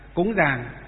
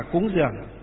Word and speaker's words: cúng dường: Miền Bắc chúng ta đọc là dàng, cúng dàng, miền Nam đọc là cúng dường.